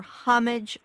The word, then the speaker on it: homage: But the proper pronunciation of that word is either homage or homage in English. Homage is a French word. So it's homage or homage. Whether you pronounce the H or not, the first syllable is accented.